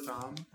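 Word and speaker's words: Thom: But there's this famous painting by Robert Thumb, that's how you pronounce it, that portrays this.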